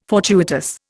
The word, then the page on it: fortuitous: Indian